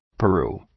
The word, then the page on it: Peru: {pə’ru:}